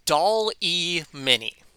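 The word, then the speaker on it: DALL·E Mini: Anyway, the second thing I have is this little website called Doll-E Mini. Spelled like Wally, but with a D for the W.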